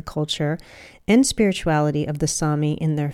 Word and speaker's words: Sami: It's spelled S-A-M-I, but it's pronounced Sami.